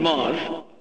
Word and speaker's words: Maus: Because the whole thing was, my last name is Moss. – Yes. – And it's spelled M-A-U-S.